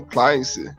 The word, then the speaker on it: Clancy: É, não sei se a pronúncia é essa, é Clancy, né? Tom Clancy.